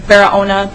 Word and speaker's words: Barahona: First name, Myrna, M-I-R-N-A. First, second last name, Serrano, S-E-R-R-A-N-O, and second last name, Barahona, B-A-R-A-H-O-N-A.